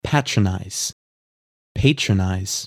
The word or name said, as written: Patronize